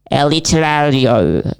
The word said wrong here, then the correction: aleatorio: Vale, esa es la pronunciación inglesa de aleatorio. – Aleatorio. – Yeah, you got it.